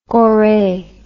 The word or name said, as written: Gorée